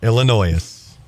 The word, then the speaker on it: Illinois: Clayton broke up with lady because she said Illinois. Or Illinois? Regardless, you don't pronounce the S.